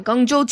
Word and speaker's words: Guangzhou: Yep and uh, Guangzhou, China.